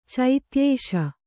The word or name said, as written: ಚೈತ್ಯೇಶ